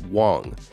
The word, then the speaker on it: Wang: FTX co-founder Gary Wang, now those are two different ones if you're not understanding the pronunciation. One is Huang and one is Wang.